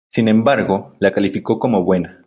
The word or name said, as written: buena